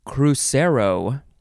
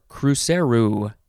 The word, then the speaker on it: cruzeiro: C-R-Y. Almost. Okay, so the first word in this episode is cruzeiro, or cruzeiro. It is spelled C-R-U-Z-E-I-R-O.